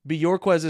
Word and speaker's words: Bjorquez: Bjorquez is how you pronounce his last name.